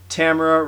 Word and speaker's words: Tamara: And tonight, we have a special guest, ladies and gentlemen, introducing Tamara, rhymes with Camera, Winter.